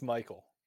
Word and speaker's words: Mycole: By the way, does he say Michael or does he actually say Michael? – It's Michael. – That's what I thought. It's Michael and it's spelled M Y C O L E.